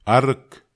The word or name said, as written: arek